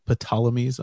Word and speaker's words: Ptolemies: the Ptolemies. I pronounce the P. And don't pronounce the P. – I pronounce the P. – Silent P.